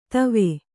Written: ಥಾವು

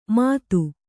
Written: ಮಾತು

